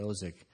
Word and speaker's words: Ozick: My favorite passage on an interview was with Cynthia Ozick. Is that the correct way to pronounce yes. – it? Cynthia Ozick.